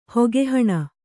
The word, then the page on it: ಹೊಗೆಹಣ: Kannada